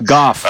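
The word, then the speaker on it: Gough: Talking to Michael Goh, I think. Is that how you say his name? – Or Goosh or something? – Goff. – Goff. – Yeah. – Okay. Goff.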